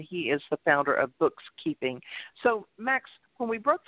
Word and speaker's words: BooXkeeping: And, of course, BooXkeeping, it's spelled with an X instead of a K.